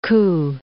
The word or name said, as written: Koo